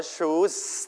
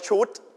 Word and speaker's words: choose: choose ต้องลงท้ายด้วยเสียง s นะอย่าออกเสียงว่า shoot